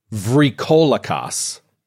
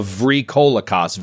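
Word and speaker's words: vrykolakas: It's called a I want to pronounce this correctly. It's called a vrykolakos. A vrykolakos, V-R-Y, it starts with.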